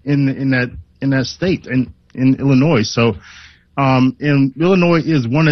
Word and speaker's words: Illinois: For those who think that Illinois is the correct pronunciation, it's not. It's Illinois.